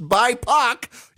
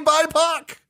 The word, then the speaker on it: BIPOC: BIPOC, an acronym for Black, Indigenous, and People of Color, pronounced BIPOC. You're actually supposed to say BIPOC.